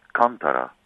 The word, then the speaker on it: Kantara: I wanted to make sure that we got a chance to talk about your newer group, Kantara. Am I saying it correctly? – If you can put the emphasis on the first syllable, Kantara.